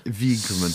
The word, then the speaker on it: Wiegman: Wiegman.